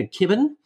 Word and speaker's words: Kibben: kibben and uh,